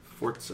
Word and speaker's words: Forza: Does that have a T in it? Forza, that's how you say it.